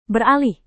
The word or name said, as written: beralih